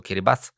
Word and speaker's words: Kiribati: Kedy si to bolo miesto, kde začínal deň, ale teraz je to už v Kiribati, respektíve oni to vyslovujú Kiribati, A tam je dokonca ten čas oproti Greenwichu posunutý o 14 hodín.